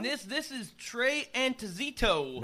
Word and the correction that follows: Anastasio: Nah, that'd be Anastasio.